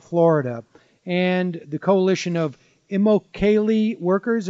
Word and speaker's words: Immokalee: It took me like a year to learn how to say it, but it rhymes with broccoli and it's pronounced Immokalee.